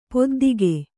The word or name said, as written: ಪೆದ್ದಿಗ